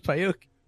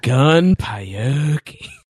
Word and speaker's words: Gunpei Yokoi: I thought his first name was Gun, and his last name was Pie Yoki.